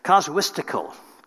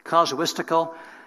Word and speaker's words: casuistical: It's really, I want to make sure I can pronounce it right. I know the word, but I don't always pronounce it very often. It's casuistical. Casuistical.